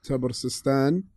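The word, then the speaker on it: Tabaristan: With a ta, Tabristan.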